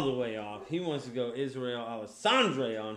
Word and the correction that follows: Adesanya: What? Alessandre? – What's, how do you say that? Adesanya? – Adesanya, sorry.